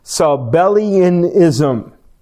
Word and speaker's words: Sabellianism: In fact, an early church history heresy, and this is a tough word to pronounce, I believe it's pronounced Sabellianism.